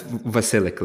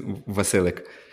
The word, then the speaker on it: Vasilik: It's Vasilik. Like, if you want to, the true Ukrainian pronunciation, it's Vasilik.